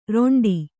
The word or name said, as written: ರೆಂಟೆ